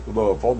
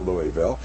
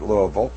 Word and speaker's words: Louisville: You notice I said that in Southern way, Louisville, not Louisville. But if you're from there, they all pronounce it Louisville.